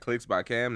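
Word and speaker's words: Klicks by Kam: you can follow me on everything at Clicks by Cam. That's K-L-I-C-K-S-B-Y. K-A-M.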